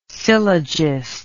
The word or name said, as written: syllogist